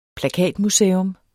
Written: plakatmuseum

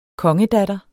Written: kongedatter

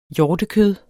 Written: hjortekød

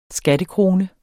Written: skattekrone

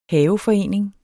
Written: haveforening

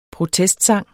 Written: protestsang

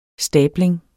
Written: stabling

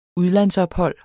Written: udlandsophold

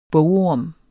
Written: bogorm

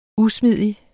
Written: usmidig

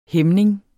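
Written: HÆMNINGER

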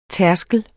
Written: tærskel

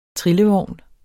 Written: trillevogn

